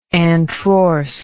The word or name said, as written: antrorsely